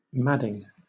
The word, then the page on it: madding: British English